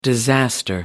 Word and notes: disaster: American English

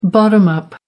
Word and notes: bottom up: American English

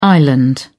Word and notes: island: British English

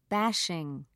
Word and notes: bashing: British English

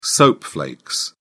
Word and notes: soap flakes: British English

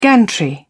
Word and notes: gantry: British English

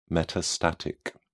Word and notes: metastatic: British English

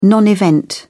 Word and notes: non-event: British English

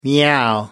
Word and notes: meow: American English

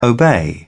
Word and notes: obey: British English

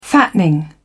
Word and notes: fattening: British English

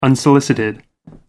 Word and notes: unsolicited: American English

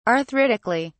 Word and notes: arthritically: British English

